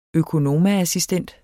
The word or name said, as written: økonomaassistent